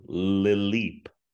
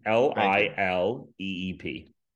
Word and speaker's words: Lileep: is called Lileep. – Lil can you can you spell that for me? I can indeed. – L I L E E P.